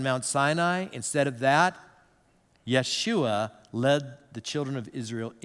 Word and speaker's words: Yeshua: There is this dude by the name of Yeshua. The English pronunciation of that word is Joshua, and it is also translated Jesus.